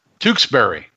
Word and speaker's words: Tewksbury: Tewksbury.